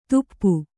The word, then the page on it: ದುಪ್ಪು: Kannada